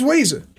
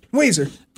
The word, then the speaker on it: Ouiser: It's Weezer. Weezer. – But do you know the way it's spelled is O-U-I-S-E-R? – Oh, yeah, Weezer. – That's like she spelled it like the band.